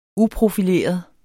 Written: uprofileret

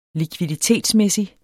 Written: likviditetsmæssig

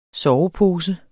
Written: sovepose

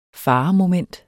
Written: FAREMOMENT

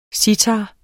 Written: citarer

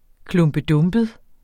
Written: klumpedumpet

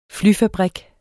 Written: flyfabrik

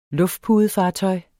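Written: LUFTPUDEFARTØJ

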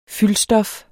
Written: fyldstof

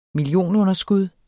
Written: millionunderskud

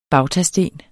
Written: bautasten

